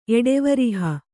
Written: ಎಡೆವರಿಹ

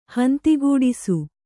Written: ಹೊಂದುಗೂಡಿಸು

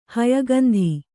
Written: ಹಯಗಂಧಿ

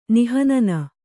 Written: ನಿಹನನ

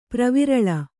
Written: ಪ್ರವಿರಳ

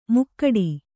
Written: ಮುಕ್ಕಡಿ

